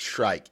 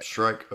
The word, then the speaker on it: Shrike: And is it Shrike or Shriek? – I think Shrike is more correct. – Shrike, okay. Just making sure. – Because, with Shriek, I think you would switch to the K and the E.